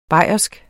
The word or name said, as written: bayersk